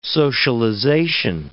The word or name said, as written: socialization